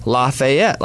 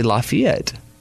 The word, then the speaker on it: Lafayette: Most people say Lafayette, like it's spelled, but it's actually Lafayette.